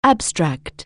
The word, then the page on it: abstract: anglais